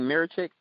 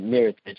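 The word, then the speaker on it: Mirotić: Man, I'm one of those people who believe that Mirotic – I think I'm. Am I saying his name wrong? Mirichik. – Yeah, Mirichik? you're saying his name right, Mirichik.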